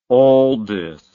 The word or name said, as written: aldis